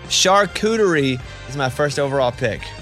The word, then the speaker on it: charcuterie: Charcuterie? – Yeah, charcuterie.